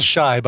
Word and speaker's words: Chi: Well, no, it's pronounced Shy, by the way.